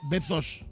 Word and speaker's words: Bezos: No, pero tú lo pronuncias con Z. – Bezos. – Exacto, ese.